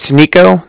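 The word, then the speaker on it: Niko: It's Nico. That's N-I-K-O.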